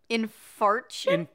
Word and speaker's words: infarction: A myocardial infarction. – Infarction? Infarction? – Infarction.